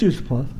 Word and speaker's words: Yusupov: But you have a scene where Kirk Johnson is talking with Felix Yusupov, I think is how he pronounced it. – Yeah. Prince Yusupov. Yeah.